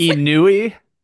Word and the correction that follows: ennui: Ennui. – ennui. I'm using the Google like pronunciation. It sounds very French.